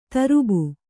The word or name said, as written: ತರುಬು